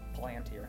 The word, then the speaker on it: Palantir: That's how the thing it's named after is pronounced Palantir. – Okay. – Because it's actually named after something in the Lord of the Rings series, I believe.